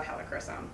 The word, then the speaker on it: helichrysum: Okay, so you just mentioned helichrysum. Is that what you said? Did I say that right? – Close. Helichrysum. – Helichrysum.